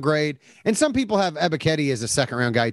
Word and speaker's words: Ebiketie: According to my pronunciation key, Ebiketie. – Yeah, there you go. Ebiketie. Yeah, you're good.